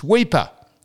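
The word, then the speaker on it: Weipa: Exactly, if you're going to advertise where Indigenous Literary Day is, it's Weeper. Wouldn't you think you'd be able to pronounce the country town properly? Weeper. He said Wiper.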